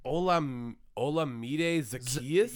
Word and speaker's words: Olamide Zaccheaus: Olamide Zaccheaus? – Yep, you just nailed it. Yeah, that's exactly how you pronounce it.